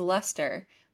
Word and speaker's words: Leicester: so Google says it's Lester, but it's pronounced or it's spelled LEICESTER. So there's just like a bunch of random letters in the middle.